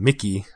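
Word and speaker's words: Mykki: The opening track, which is called Mickey, I think that's how it's pronounced. It's M-Y-K-K-I, so it's Mickey or Mickey.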